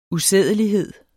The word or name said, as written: usædelighed